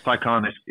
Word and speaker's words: Taycan: I'm not sure how you pronounce it in English, because in Norway it's Taycan. But Taycan is right, yeah.